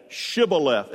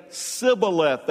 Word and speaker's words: Shibboleth: For he could not pronounce it right. He would say Sibboleth.